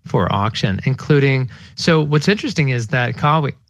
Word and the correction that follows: Kawhi: how do you pronounce his name, Tex? Kawi? Kawi? – Oh, um, Kawhi. Kawhi.